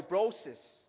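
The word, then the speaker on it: brosis (βρῶσις): it was the Greek word brosin. Okay? I'm sorry, brosis. Brosis. B-R-O-S-I-S-N. But it's pronounced brosis.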